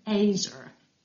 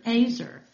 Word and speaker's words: ezer: If ever there has been a word lost in translation, it is the Hebrew word ezer, E-Z-E-R. Some pronounce it as rhyming with razor.